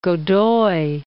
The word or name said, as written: Godoy